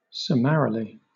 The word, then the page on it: summarily: British English